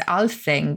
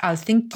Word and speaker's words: Alþingi: And in Iceland, of course, you've still got the, it's written as Alþing, but I think it's pronounced Alþingi.